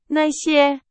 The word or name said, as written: 那些